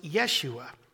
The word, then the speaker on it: Yeshua: The name Jesus in the Hebrew is pronounced Yeshua,